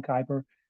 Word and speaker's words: Kuiper: Oakland Athletics broadcaster Glenn Kuiper, I believe that's how you pronounce his last name, Kuiper, has recently been suspended.